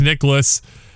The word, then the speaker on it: Nicklaus: How do you say his name? I always thought it was Niklas or something. – No. – Jack Niklas. – That's your German 101 coming back in from KU. – Let's be real. There's a whole lot of nothing in the golf world between Jack Niklas and... Oh, geez, I can't even say it right now. Between Jack Nicklaus and Tiger Woods.